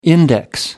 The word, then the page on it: index: American English